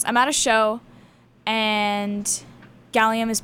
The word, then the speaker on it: Gxllium: And the one before that was Who Killed Cassie by Gallium off of Gallium 1. And just FYI, Gallium is spelled G-X-L-L-I-U-M.